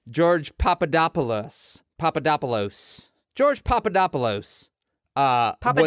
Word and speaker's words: Papadopoulos: up at it's Papadopoulos, and I know this, Maureen, because I spent an extensive amount of time on YouTube channels that just tell you how to pronounce things, which is a weird set of YouTube channels.